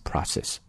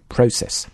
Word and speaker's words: process: 然后注意一下美式发音 process, process, OK, process, 英式发音 process。